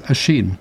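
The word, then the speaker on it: Oisín: Well, but I had to ring the BBC pronunciation department when I first discovered this, because I didn't know how to pronounce Oisin, which is O-I-S with I-N with an accent over it. So it's Oisin. – Oh. – And Finn, of course, it looks like Fionn, F-I-O-N-N, but it's Finn.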